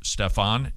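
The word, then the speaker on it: Stephan: Phil, would you, if somebody were, I'll just kind of paraphrase this email that was sent in from, I believe it's pronounced Stefan, it's S-T-E-P-H-A-N,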